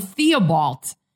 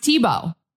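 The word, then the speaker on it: Thibault: I call them Theobald, but it's pronounced Tebow.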